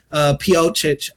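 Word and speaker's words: Pocic: And they go and get Ethan Piocic, I think that's how you say his name, that was the center for the Browns.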